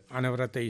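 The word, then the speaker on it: Anawrahta: And then when the king Anuruddha, Anavrata is the Burmese pronunciation, came to know this is not the Buddhism.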